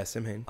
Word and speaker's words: Samhain: Halloween's origins come from a Celtic festival for the dead called Samhain. I don't know if I'm pronouncing that correctly. – Yeah, Samhain.